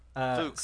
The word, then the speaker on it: folk: You said it correctly the first time. – Which folk? – Fook. – Scotland. That's how the Scotland say it. Scottish say fook.